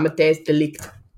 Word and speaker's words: Matthijs de Ligt: I already got Matthijs de Ligt down perfect. So, I even did the little emphasis on the de Ligt part.